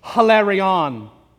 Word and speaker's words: hilarion: That the Greek word cheerful is hilarion, and it's usually pronounced with that aspirant H, hilarion.